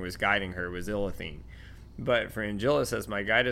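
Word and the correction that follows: Ithlinne: It's Ithilene, not Illithine. – Ithilene. – It's Ithilene. – I'm the worst with names. – It's I-T-H. It's just Ithilene, like not L.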